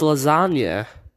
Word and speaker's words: lasagna: He is in fact talking about he is talking about the way that you pronounce lasagna. You pronounce lasagna without the G, but when you spell lasagna, there's a G. So the G is silent.